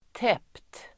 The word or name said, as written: täppt